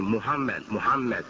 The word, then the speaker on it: Muhammad: And that was made possible by the phonetic spelling M-U-H-A-M-M-A-D. The previous spelling was also French-influenced spelling, M-O-H-A-M-M-E-D, M-O-H-A-M-M-E-D. And it's pronounced Muhammad. But the West pronounced it because of the spelling. They tried to pronounce it phonetically, and they said Mohammed.